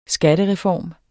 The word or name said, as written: SKATTEREFORM